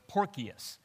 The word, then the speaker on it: Porcius: Anyway, his name is Porcius Felix, which the name Porcius, literally, there's no soft C in Greek, so it's Porcius is his real name, which means swinish, and then Festus is from the Greek word for festival, so literally his name means pig party.